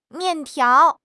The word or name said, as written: mian4 tiao2